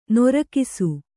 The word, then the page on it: ನೊರಕಿಸು: Kannada